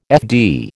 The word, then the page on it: FD: American English